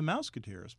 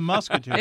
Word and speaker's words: Musketeers: oh, it's the Mousketeers. But no, it's the Musketeers.